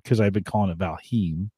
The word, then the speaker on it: Valheim: I think we're both pronouncing it wrong. I think this is how it's pronounced. Did you hear that? – No. – Oh, so shoot. That should have switched over. One second here. Maybe I don't have the loop back. I think it's Valheim. – It might be.